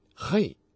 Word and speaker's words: Hei: 现在编号为 4039 和 4040 的这两个希伯来词都有相同的辅音字母 M-E-M、 G-I-M-E-L 以及 L-A-M-E-D。此外它们末尾还有一个字母 H-E-I、嘿，听起来很像希伯来的 H 的发音。